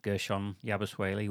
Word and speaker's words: Gershon Yabusele: Gershon Yabusele, which is a fantastic name.